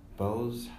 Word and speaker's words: Bowles: Like, Mrs. Bowles, is how you say it, I think? – Yeah.